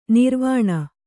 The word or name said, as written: ನಿರ್ವಾಣಿ